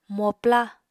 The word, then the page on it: mopla: Apatani